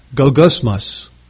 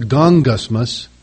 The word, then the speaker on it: goggusmos: The word for complaining in verse 14 is the Greek word, is translated from the Greek word gogosmos. It's actually pronounced gongosmos, but it's deceptive. The spelling is G-O-G-G. G-G in the Greek is pronounced with like an N-G in English. G-O-G-G-U-S-M-O-S. Gongosmos.